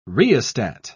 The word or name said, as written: rheostat